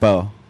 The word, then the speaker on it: Spui: Satisfied when you get to Spo, which is spelled S-P-U-I. Thank you very much. – S-P-U-I, and it's pronounced Spo.